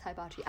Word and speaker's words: hibachi: It's hibachi. I did Japanese karate. I know just enough Japanese to know it's pronounced hibachi.